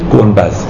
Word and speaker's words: گنبد: هنوز خیلی از جاها به گنبد میگن چی؟ گنبز. این دقیقا بازمانده همونه که ایرانی ها دال رو زال تلفظ میکردن.